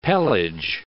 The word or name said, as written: pelage